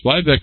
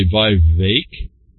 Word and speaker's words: Vivek: Vivek rhymes with cake. He says Ramaswamy, 38. I guess I should say Vivek.